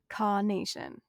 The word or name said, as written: Kaw